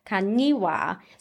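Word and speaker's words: Kañiwa: All I know is there's a little tilde over the N. So it's like quinoa, but it's kaniwa.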